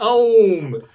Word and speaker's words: Eom: St. Om. Om. Okay, they got it. It's spelled E O M here. – Yeah. And I, maybe it is E.M. I, that, I pronounce it Ohm.